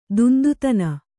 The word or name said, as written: ದುಂದುತನ